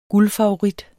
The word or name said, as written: guldfavorit